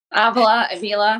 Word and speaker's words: Avila: Avila, it's all the same.